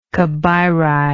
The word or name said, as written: Cabiri